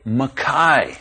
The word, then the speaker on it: McKay: And don't pronounce M-C-K-A-Y as McKay, you say Mackay.